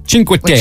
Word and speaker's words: Cinque Terre: Cinque Terre.